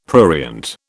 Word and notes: prurient: British English

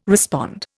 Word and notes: respond: Indian

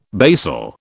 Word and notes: basal: British English

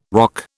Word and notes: roque: British English

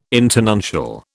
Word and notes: internuncial: British English

